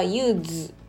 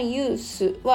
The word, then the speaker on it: use: で動詞の時はユースのこの発音本来はユーズと濁音っぽくなるんですけど名詞名詞としてのユースは S の発音が S のままなんですね。